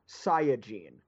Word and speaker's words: Saiyajin: In the Japanese, the term is Saiyajin. So I think Saiyan is the correct pronunciation, but every dub has gone with Saiyan.